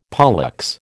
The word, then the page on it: pollex: American English